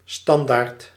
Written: banner

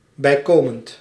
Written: bijkomend

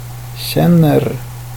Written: känner

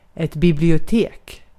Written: bibliotek

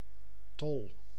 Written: top

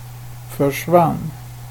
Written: försvann

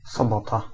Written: Saturday

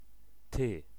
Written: thou

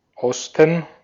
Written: east

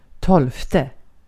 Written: tolfte

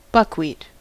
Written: buckwheat

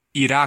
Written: Iraq

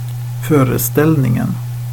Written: föreställningen

